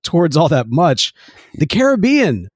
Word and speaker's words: Caribbean: And Walt pronounced it Pirates of the Caribbean, so that's what it was.